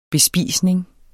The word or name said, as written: bespisning